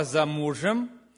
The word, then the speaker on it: замужем: Она зашла и стала за мужем, и положила руки на плечи мужу и ответила: «Вот я стою за мужем». «Замужем», «замужем», ударение другое.